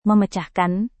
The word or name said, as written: memecahkan